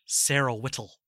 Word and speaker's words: Sarah Whittle: her name is Sarah Whittle, or as Robin Williams pronounces it, Sarah Whittle. Very, very sharp T when he pronounces it.